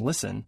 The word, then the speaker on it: listen: Just as you don't say the T in listen, the TT in better is pronounced D, better.